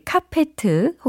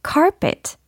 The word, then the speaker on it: carpet: 네, 카페트 혹은 양탄자에 해당하는 영어 발음을 집중적으로 연습을 해보려고 하거든요. 카페트는 영어로 카페트 땡 아니고 carpet, carpet 이렇게 발음을 해주셔야 되겠습니다. C-A-R-P-E-T 이 철자로 이루어져 있어요.